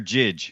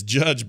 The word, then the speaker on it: Jydge: Now, is it Judge or Jidge? – It's Judge, but it's spelled J-Y-D-G-E.